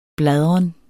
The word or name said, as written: bladren